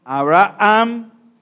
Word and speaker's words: Abraham: No suena la H muda. No es Abraham porque no suena J la H, suena muda. Abraham y decimos Abraham.